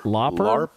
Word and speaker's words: Lauper: Lauper.